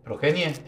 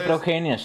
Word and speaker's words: progenie: No se dice progenia, se dice progenie.